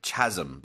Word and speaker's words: chasm: The interesting one, chasm. So it looks like chasm, but it's pronounced chasm.